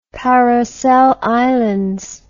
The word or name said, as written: Paracel Islands